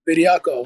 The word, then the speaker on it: Bediakoh: Bediakoh.